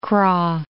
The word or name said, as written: Kra, Isthmus of